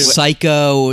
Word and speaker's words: Saiko: It's not P-H-Y. – Spelled like Psycho, like Psychopath. It's spelled like S A I K O.